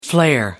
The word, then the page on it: flare: American English